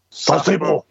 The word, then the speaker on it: Sasebo: So I remember specifically when I was trying to the cue from Rodan, Rodan flies to Sasebo, or Sasebo. Is my pronunciation correct? – Sasebo.